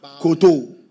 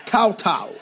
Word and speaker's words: kowtow: Comme par exemple, tu dis à quelqu'un, il y a un mot en anglais, koto, ça veut dire de fléchir les genoux. Mais ce n'est pas ça. La prononciation n'est pas koto. La prononciation, c'est kowtow.